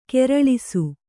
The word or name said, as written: ಖರೆಲೇಸು